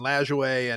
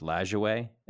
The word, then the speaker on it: Lajoie: Well, okay, so it's actually quite controversial, because I used to say Lajoie, like Bill Lajoie. – That's how I've always said it. – Right, and then I had someone who had just done a big, huge profile on him on Knapp, and he said, no, it's Lajoie. It's pronounced Lajoie, and that's the French.